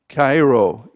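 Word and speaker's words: chairo: So I just want to clarify to any of you who think that we've been butchering this name that it is really the way it's said. But Cairo is to rejoice in accordance with grace.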